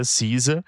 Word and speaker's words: SZA: CISA, é. que na verdade se escreve S-Z-A, mas se pronuncia CISA.